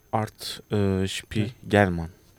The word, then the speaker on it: Art Spiegelman: Art Spiegelman. – Evet, hı? Doğru telaffuz ettin.